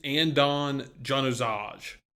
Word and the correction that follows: Adnan Januzaj: Adnan Januzaj. – Okay, that's actually pretty good for me.